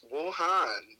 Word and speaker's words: Wuhan: I can't pronounce it right. – Wuhan, there we go.